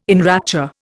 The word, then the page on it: enrapture: Indian